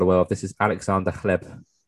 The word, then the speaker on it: Hleb: Yes, Chleb. H-L-E-B. Chleb.